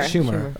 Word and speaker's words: Schumer: Sorry. Schumer. Okay, I just wanted to pronounce it correctly.